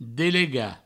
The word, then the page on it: delegar: Provençal; delega